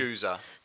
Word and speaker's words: gueuze: The second one I can't even pronounce. G-U-E-U-Z-E. – Yes, Guza.